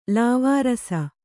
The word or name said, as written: ಲಾವಾರಸ